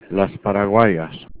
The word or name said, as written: paraguayas